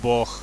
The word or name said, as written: boh